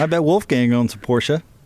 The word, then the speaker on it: Wolfgang: I believe it's pronounced Wolfgang. – Wolfgang. – Not like a gong? Like a giant cymbal? – Well, like Wolfgang. You know, no, it's German Wolfgang.